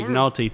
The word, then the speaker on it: McNulty: McNulty.